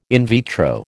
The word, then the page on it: in vitro: American English